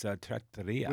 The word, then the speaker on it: trattoria: I used to think the pronunciation was Trattoria, but it's Trattoria.